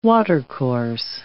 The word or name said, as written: watercourse